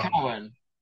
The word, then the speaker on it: Keown: In Tennessee, I guess, first of all, it's my last name's actually pronounced Cowan.